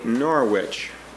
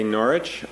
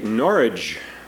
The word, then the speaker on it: Norwich: Now, most of us would say Norwich, but in Britain, they never pronounce all the letters. So when you get there, you find that they either say Norwich, or if they're really deep, they'll say Norwich, just like porridge.